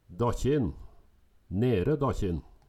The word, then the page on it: Dakkjin, Nere Dakkjin: Numedalsmål